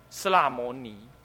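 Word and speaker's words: 室罗摩拏: 那么这是中原的古音呢，啊，所以要念成呢斯拉摩尼，啊，那么呢又译为求己，所以以上有三义。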